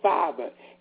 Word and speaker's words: Fiverr: I think I'm pronouncing it right, Fiverr. F-I-V-E-R-R.